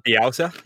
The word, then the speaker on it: Bielsa: Is it Bielsa? – Bielsa, yeah.